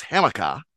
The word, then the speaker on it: Hamilcar: We've got to go with a Boston accent there. It's Hamika. And it's Rashid, like Rashid Wallace.